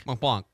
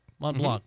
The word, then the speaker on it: Mont Blanc: I'm not sure if I'm saying this right, but Mont Blanc? Mont Blanc? – Mont Blanc. – Mont Blanc, mm-hmm. Yeah. – Like the pens. Mont Blanc pens?